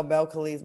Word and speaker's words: Belcalis: Belcalis. – Oh, Belcalis, my bad.